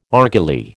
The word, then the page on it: argali: American English